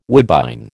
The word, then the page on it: woodbine: American English